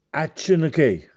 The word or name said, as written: Accenechè